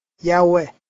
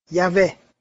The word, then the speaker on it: Yahweh: Jehovah, Jehovah. Nhưng ngày nay, các chuyên viên ngôn ngữ cho rằng cách phát âm có hy vọng đúng nhất vẫn là Yahweh, Yahweh.